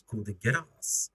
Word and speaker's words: Coup de Grâce: Det är Coup de Grâce, men det ska uttalas Coup de Grâce. Är det så? – Coup.